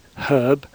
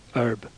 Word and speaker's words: herb: Some words like herb have h in RP, but not in American. Americans say herb.